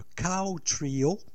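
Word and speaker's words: Qow Trio: On va conclure le présent bloc avec le Cow Trio. Ça se prononce comme une vache, mais ça s'écrit avec un Q.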